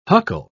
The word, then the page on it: huckle: British English